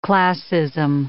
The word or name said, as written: classism